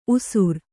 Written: ಊಷರ

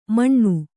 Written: ಮಣ್ಣು

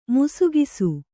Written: ಮಿಸುಗಿಸು

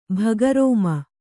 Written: ಭಗರೋಮ

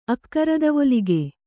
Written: ಅಕ್ಕರದವೊಲಿಗೆ

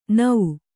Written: ಣ

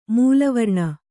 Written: ಮೂಲವರ್ಣ

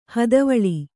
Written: ಹದವಳಿ

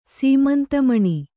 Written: ಸೀಮಂತಮಣಿ

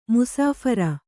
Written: ಮುಸಾಫರ

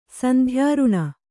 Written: ಸಂಧ್ಯಾರುಣ

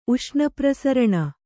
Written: ಉಷ್ಣಪ್ರಸರಣ

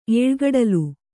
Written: ಏಳ್ಗಡಲು

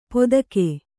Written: ಪೋದಗೆ

